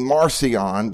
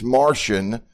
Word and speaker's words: Marcion: And so there was a guy named, now I call him Marcion, because if you call him by his real name, it's Martian, M-A-R-C-I-O-N. People think he's from the planet Mars, but it's not M-A-R-T-I-A-N. It's M-A-R-C-I-O-N. It's pronounced Martian, but I call him Marcion so that people will know we're not talking about some extraterrestrial being.